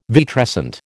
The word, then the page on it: vitrescent: American English